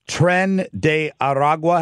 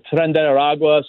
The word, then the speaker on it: Tren de Aragua: So what do we know about, what is it, Tren de Aragua? Have I pronounced that correctly? – Yeah, you definitely did. So Tren de Aragua, some people call them TDA or El Tren.